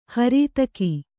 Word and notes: ಹರಿತಕ: Kannada

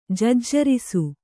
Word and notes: ಜಜ್ಜರಿಸು: jajjarisu

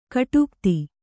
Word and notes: ಕಟೂಕ್ತಿ: kaṭūkti

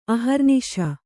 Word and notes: ಅಹರ್ನಿಶ: Kannada

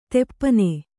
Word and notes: ತೆಪ್ಪನೆ: kannada; teppane

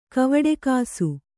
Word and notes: ಕವಡೆಕಾಸು: Kannada

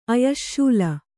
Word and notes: ಅಯ್ಸಲೇ: aysalē

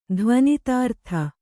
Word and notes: ಧ್ವನಿತಾರ್ಥ: kannada

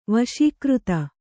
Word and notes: ವಶೀಕೃತ: vaśikřta

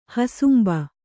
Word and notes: ಹಸುಂಬೆ: Kannada

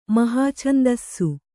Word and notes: ಮಹಾಛಂದಸು: mahāchandasu

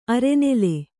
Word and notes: ಅರನೆಲೆ: Kannada

